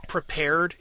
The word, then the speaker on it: Prepar3D: There's an application that you can get called it's pronounced prepared. It's spelled PREPAR3D, prepared with like a 3D on the end of it.